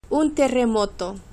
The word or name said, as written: un terremoto